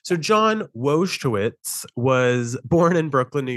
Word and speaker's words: Wojtowicz: So John, I'm going to try to pronounce this correctly. Wojtowicz. – I was going to say Wojtowicz. – That sounds right. John Wojtowicz. There we go.